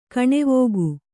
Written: ಕಣೆವೋಗು

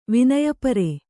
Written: ವಿನಯಪರೆ

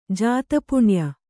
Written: ಜಾತಪುಣ್ಯ

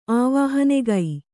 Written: ಆವಾಹನೆಗೈ

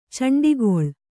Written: ಚೆಂಡಿಗೊಳ್ಳು

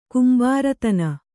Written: ಕುಂಬಾರತನ

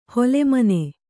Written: ಹೊಲೆಮನೆ

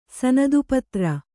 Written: ಸನದುಪತ್ರ

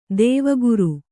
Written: ದೇವಗುರು

